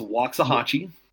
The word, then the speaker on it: Waxahachie: That is pronounced Waxahachie. – Waxahachie.